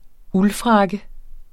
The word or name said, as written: uldfrakke